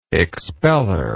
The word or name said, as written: expeller